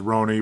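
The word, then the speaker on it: Ronny: I guess it is pronounced Roni. We keep calling him Ronnie, but...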